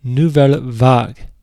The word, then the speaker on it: Nouvelle Vague: Our first song up is from the continent of Europe from 2004, and it is titled In a Manner of Speaking by Nouvelle Vague. And I think I'm saying that right. It's a French band. Vague is like the English word vague.